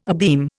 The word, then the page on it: abeam: Indian